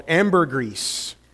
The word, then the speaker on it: ambergris: but this thing called ambergris. Not grease, but it's a whole word together. Ambergris, not axle grease, ambergris. And it's this extract that is really difficult to obtain.